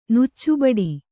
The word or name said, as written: ನುಚ್ಚುಬಡಿ